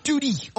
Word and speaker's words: Tottie: Là, vous connaissez bien comment on prononce T-O-T-T-I-E, Tootie.